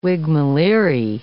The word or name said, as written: whigmaleerie